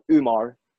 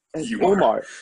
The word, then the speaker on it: Umar: And then he would always, like, because my name's like such a weird spelling, he was like, oh, come on, Umar. Or like, Umar. And I was like, Umar. And he was like, okay, I'm sorry. I'll say it right.